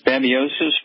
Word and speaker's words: babesiosis: is B-A-B-E-S-I-O. S-I-S. – Babiosis. – That would be about right.